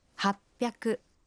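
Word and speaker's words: Хаппяку (はっぴゃく): А 800… «Хаппяку».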